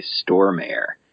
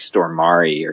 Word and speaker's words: Stormare: I always say Stormare, but I know that that's not right. It's like Stormari or something.